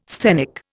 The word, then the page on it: sthenic: Indian